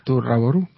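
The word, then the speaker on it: To Love-Ru: Es porque no, en japonés se pronuncia así. Tu es to, love es rabu, y ru... – Tu rabo. – Tu raboru.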